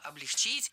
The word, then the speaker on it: облегчить: Облегчили, облегчить – это неверно. В нашем глаголе ударение всегда стоит за корнем. Облегчило, облегчить.